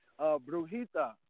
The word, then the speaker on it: Brujita: Is it Brujita? – Brujita. See, it's Brujita. That J is an H in Spanish.